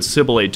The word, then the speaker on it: sibilate: All right, and the other word of the day is sibilate. I believe that's how it's pronounced. Let's go to our computer for confirmation. – Nice. – Thank you, computer. I'm going to go with sibilate.